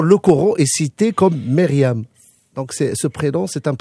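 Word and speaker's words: Myriam (مريم): Est-ce que je la prononce bien? Myriam, c'est ça? – En arabe, c'est Myriam. – Myriam. – Myriam. C'est correct.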